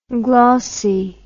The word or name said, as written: glauce